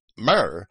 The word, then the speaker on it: myrrh: I would call the ointment myrrh and pronounce that Y as a U, because it is in Greek also.